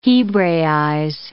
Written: hebraize

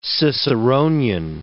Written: Ciceronian

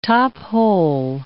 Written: top-hole

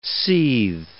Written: seethe

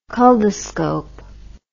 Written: culdoscopy

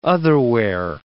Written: otherwhere

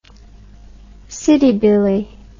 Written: citybilly

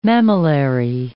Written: mammillary

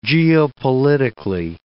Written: geopolitically

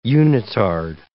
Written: unitard

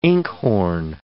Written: inkhorn

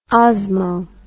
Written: osmo

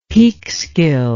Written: Peekskill

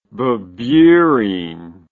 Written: bebeerine

